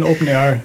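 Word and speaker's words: OpenAIRE: Das ist A-I-R-E. – Ja, aber das spricht man immer noch R aus. – Das spricht man immer R aus, okay. Aber gut, das heißt dann Open Air.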